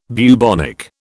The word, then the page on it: bubonic: British English